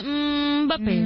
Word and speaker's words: Mbappé: ¿Cómo se dice, Mbappé o Mbappé? – Mbappé. Se dice Mbappé.